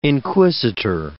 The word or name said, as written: inquisitor